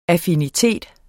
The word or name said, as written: affinitet